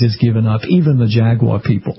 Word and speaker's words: Jaguar: pronounce Jaguar the way they do in the United Kingdom, and that's a Jaguar.